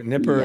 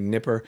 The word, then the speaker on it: Dnieper: It's spelled with a D. – I've heard it both ways, Dnieper. – Nipper. – Yes. Most people in English now say Nipper.